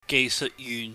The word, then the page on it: geihseuhtyuhn: Cantonese